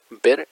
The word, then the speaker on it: Bare: Además se llama Nick Ver, ese ver se escribe B-A-R-E.